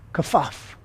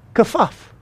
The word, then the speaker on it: kafaf: But you know what this, the word is kafaf. Kafaf. That's how you pronounce it.